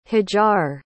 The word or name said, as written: hajar